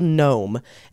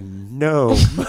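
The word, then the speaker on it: Noam: Is it by Gnome? – I assume that's how it's pronounced. It's N-O-A-M. Rhymes with foam. Looks like foam with an N.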